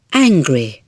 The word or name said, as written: angry